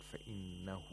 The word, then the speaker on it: فإنه: We have إشباع of ها الضمير, and the word فإنه, the ها has to be pronounced like a long vowel because it's preceded by a letter with a حركة, with a short vowel.